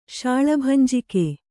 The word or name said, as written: ಶಾಳಭಂಜಿಕೆ